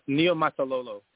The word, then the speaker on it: Niumatalolo: It's Niumatalolo is the pronunciation there. So if you look at it long enough, it actually you pronounce it exactly how it looks.